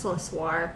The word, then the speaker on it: La Soiree: Well, Soiree is a party in French, so I think it's just La Soiree.